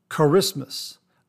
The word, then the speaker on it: Kiritimati: Now, the other holiday shave soap, Christmas shave soap that I wanted to talk about from Phoenix Shaving is spelled K-I-R-I-T-I-M-A-T-I, and it's pronounced Charismas.